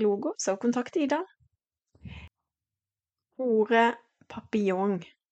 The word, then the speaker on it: papillon: Jeg måtte faktisk ringe til en fransk eh, venninne og spørre hvordan uttaler du papillon, sommerfugl, på fransk, så Sigurd, det uttales papillon. Papillon. Og det er litt viktig å uttale det kanskje riktig, da.